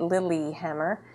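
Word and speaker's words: Lillehammer: That's not spelled correctly, but it's how Americans say it, so that's why they've named it Lilyhammer.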